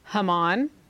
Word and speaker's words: Heman: His brother's name was Heman. It's probably pronounced Haman. But when we spell He-Man, just to let you know, because He-Man is fun to say.